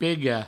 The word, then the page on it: pegar: pega; provencal